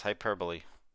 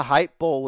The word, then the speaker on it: hyperbole: Yes, hyperbole. Hyperbole, yeah. As we used to say, because we weren't really ever taught how to say it until, like, much later on. I thought the hype bowl was another football thing that I wasn't aware of. – The hyper bowl.